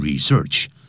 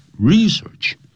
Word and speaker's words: research: research 是不可数，也不要念成 research。 research 重音在第一音节。